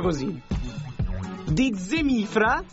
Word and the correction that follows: Zemfira: No, di Zemfira.